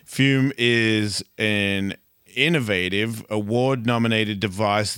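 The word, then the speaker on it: Füm: We're talking about our sponsor, Fume, pronounced Fume. F-U-M. It's got a little wiggly thing above the U.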